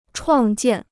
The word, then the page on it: 创建: chuàng jiàn